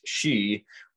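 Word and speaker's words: sìth: But in Scots Gaelic, In Scottish, it is not pronounced Sith, it is pronounced she, which is nearly identical to the Irish Gaelic she, which is spelled in English S-I-D-H-E.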